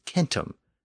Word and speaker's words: centum: In Latin, the word for 100 was centum, spelled C-E-N-T-U-M. Keep in mind that the C was always pronounced as a K in Latin. In Sanskrit, the word was Satem, spelled S-A-T-E-M.